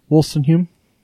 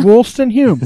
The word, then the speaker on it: Woodstenhulme: So thanks again to our guest, Kendrick Wilson-Hume. Did I say it right? – I feel like... – Pretty close. – I did. No, that's exactly Wollstone Hume.